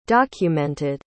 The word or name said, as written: documented